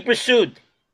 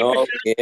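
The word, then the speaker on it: Bressoud: Eddie Pursued. Remember Eddie Pursued? – Yeah. – Would you say Pursued or Pursue? – Pursue. – Did you pronounce the D? – No.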